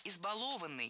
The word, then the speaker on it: избалованный: Избалованный. Неверно. Правильно. Избалованный. Опять же, ударение на корень не ставим. Избаловать. Избалованный. И на этом пришло время прощаться.